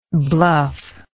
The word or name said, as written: bluffy